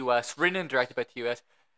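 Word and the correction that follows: Ti West: I think it's really Ty West, sorry. – Ty West? – Is it Ty West or T. West? – I think it's Ty West. – Whatever, it's spelled T-I. – I think it's just supposed to be Ty.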